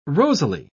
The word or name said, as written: rosily